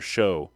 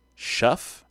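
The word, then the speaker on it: Shough: I thought it was Tyler Show, but it's Shuff, I think is how you pronounce that.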